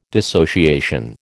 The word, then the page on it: dissociation: American English